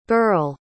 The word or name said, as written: berle